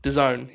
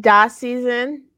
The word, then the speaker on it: DAZN: That's how you say it? – DAZN, yeah. – I've been saying Da Season.